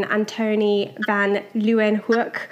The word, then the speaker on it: Antoni van Leeuwenhoek: And she works in Antoni van Leeuwenhoek Hospital. It's a specialist cancer hospital in the Netherlands. Have I pronounced that correctly, Meryl? – Yes, perfect.